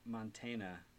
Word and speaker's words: Mantegna: I think it's Joe Mantegna. – Mantegna. I think Joe Mantegna, but that sounds like Joe Mantegna.